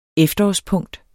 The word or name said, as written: efterårspunkt